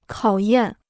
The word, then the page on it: 考验: kǎo yàn